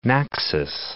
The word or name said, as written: Naxos